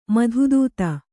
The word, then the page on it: ಮಧುದೂತಿ: madhu dūti